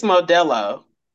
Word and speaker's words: Modelo: Morbello, what do they call it? Morbello. Was it Mordello? – The Spanish beer? – It's Modelo.